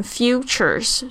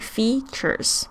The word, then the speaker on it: features: 这个 features 常常有学生会念成 futures 是错的。好，这个要注意一下，e 是发 e 的长音 features。